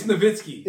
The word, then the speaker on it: Nowitzki: Dirk Nowitzki or Nowitzki? – It's Nowitzki. – It's Nowitzki. – Get his name right.